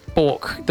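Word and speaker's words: Björk: And we're going to kick off the show with a bit of Bjork. – Bjork. – This is gotta say it right, man. – Bork.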